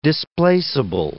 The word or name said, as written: displaceable